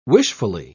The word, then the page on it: wishfully: British English